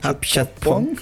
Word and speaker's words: Apichatpong: De forma virtual va a estar el director tailandés Apichato. Ah, se me fue la pronunciación correcta, es Apichatpong.